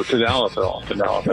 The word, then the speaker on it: Tadalafil: Tadalafil. – Tadalafil. You left the L out. – Go ahead. Oh, Tadalafil.